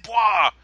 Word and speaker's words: Bois: Did you notice she said Bois? She said Rebecca Bois. – I did, and I told Milo that Trent thinks that's hilarious. – But they never pronounced it. We read it. – Okay. – And we're the ones that came up with Bois, and she said Bois, and I was like, fuck you, we were right.